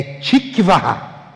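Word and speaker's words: tikvah (תקווה): A palavra esperança em hebraico é tikvah.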